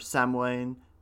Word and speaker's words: Samhain: There's another type that's more around Halloween time, which is like Halloween or Samhain, as I think it's pronounced, the earlier Celtic one.